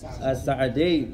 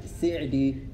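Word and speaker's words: السعدي: As-Sa'di. You can also say his name another way. As-Sa'di and? As-Sa'di. Al-Fatha and Kasra. Sa'di, Si'di. Sa'di, Si'di. You can say both ways.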